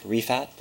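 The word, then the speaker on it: Rifaat: My name is Rasik Rifat. The last name is spelled R-I-F as in Frank, A-A-T.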